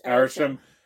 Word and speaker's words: Arishem: Then when they finally introduce, is it Arishem? Am I pronouncing that right? – Yes, Arishem.